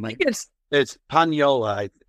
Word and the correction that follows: Paniolo: Paniolo. Yeah. So Paniolo's there too.